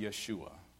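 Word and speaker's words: Yeshua (ישועה): The Hebrew word for salvation is pronounced Yeshua.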